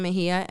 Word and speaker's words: Mejia: So it's Y-A-S-H-I-R-A. Last name Mejia, M-E-J-I-A.